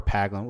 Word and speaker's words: Paglen: Um, tell tell me the, the story is about Trevor Paglen.